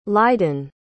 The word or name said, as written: lydon